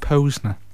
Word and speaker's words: Posner: I heard somebody the other day pronounce his name Mike Posner. – It's Posner. – It is Posner, is it? – It is definitely Posner.